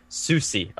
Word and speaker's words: Soucy: Uh, Soucy, or how do you pronounce it? – What Soucy. Soucy. Okay.